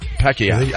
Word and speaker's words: Pacquiao: That's how they say it. Pacquiao.